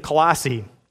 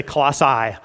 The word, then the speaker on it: Colossae: I have always wanted to say Colossae for this group that he writes, you know, to the Colossians. But apparently the city is pronounced Colossae.